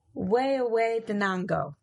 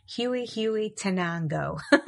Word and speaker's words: Huehuetenango: And let me see if I can pronounce this. Now, don't laugh, okay? It's Huehuetenango. It looks to me like Huehuetenango, because I do not speak Spanish.